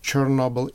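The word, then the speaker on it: Chornobyl: But the original name of the city or town after which the power plant was called is pronounced Chernobyl in Ukrainian. So it's O instead of E.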